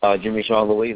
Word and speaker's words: Jimmy Jean Louis: So how'd you get Jimmy Jean Lewis? – Jimmy Jean Louis, that's the French pronunciation. He's Haitian.